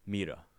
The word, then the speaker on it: Mira: It's spelled Mira, but how did you pronounce it? – Mira. – Mira. – Yeah. – Okay. – You gotta roll the R, you know? – Yeah.